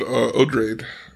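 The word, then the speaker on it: Odrade: So we had, let's talk about Odraide. That's how you say her name.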